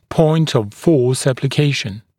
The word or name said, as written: point of force application